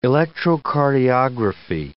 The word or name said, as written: electrocardiography